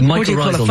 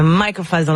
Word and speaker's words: mycorrhizal: And you compare it to the fungal network between beneath us, how do you say it? – Yes, the mycorrhizal network. – The mycorrhizal network, thank you, which is beneath us all the time.